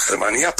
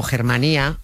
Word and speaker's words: Germanía: Utilizan la Germanía para sustentar este poder. – Lo que pronunciamos los castellanos a lo bruto como Germanía es en realidad Germanía, en Valencia. Germá es hermano, Germanía, hermandad.